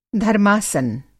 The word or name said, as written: धर्मासन